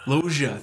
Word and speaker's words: Loggia: is it Loggia? – Robert Loggia. – Loggia, thank you.